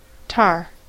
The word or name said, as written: tar